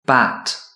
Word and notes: bat: British English

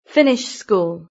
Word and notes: finish school: British English

